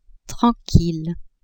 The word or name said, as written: tranquille